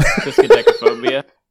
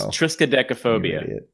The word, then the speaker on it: triskaidekaphobia: and you've heard of triskaided. Oh, boy, I can't say it. – Fear of 13. – Yeah, that one. – Triskaidekaphobia.